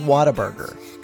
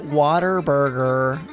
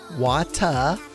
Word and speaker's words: Whataburger: I get made fun of for the way I say it, but it's pronounced Whataburger, and it's not Whataburger. I know that's what people think I say, water, but I do not. I say Whata. Whata Burger. Whata Burger. Whata Burger. There you go.